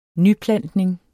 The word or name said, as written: nyplantning